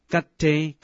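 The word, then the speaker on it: Gatte (गते): 这首咒语是古印度梵文音译成汉文的，梵文的读音是 Gatte Gatte Pra Gatte Pra Sam Gatte Bodhisvaha。什么叫做 Gatte 呢？ Gatte 是前去或者前往，无论是前去或者前往都是譬如修道的行为，勇往直前地去排除烦恼，前往觉悟的彼岸。 Gatte Gatte 即是勉励修行者要前往修行的道路。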